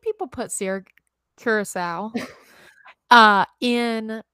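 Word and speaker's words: Curaçao: Did I say that right? – Siracao or Curacao? – I don't know. Curacao. I think it's Curacao with that U.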